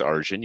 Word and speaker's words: Arjun: Yes, my name is Arjun.